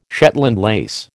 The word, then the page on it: Shetland lace: American English